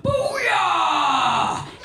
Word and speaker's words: Booyah: And then at that very moment, my phone's custom alert ringtone alarm of Kate McKinnon saying, "Booyah!" Emphasis on the boo.